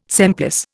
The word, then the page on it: semplice: Indian